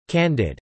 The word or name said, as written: candid